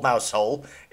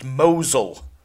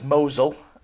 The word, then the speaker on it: Mousehole: Or so we thought it was called Mousehole. It's not. Many, many, many people, and we're talking double figures of people, have emailed in to explain that it's actually pronounced Mosul. – Oh, right, okay. – It's not Mousehole, it's Mosul. So apologies for those who explained nicely, but not to the arseholes that didn't.